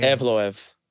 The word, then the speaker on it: Evloev: Evloev.